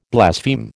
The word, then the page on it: blaspheme: American English